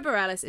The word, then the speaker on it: Bareilles: We've been trying to discuss how to pronounce her surname. – We had to Google someone saying her name just to get it right. – Sarah Bareilles is what we're going for, and it's based on a 2007 film of the same name.